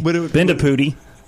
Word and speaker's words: Bendapudi: Under Dr. Bendy Nilipudi. Bendapudi. Gosh, see, I was so focused on just nailing her name correctly, and I butchered it.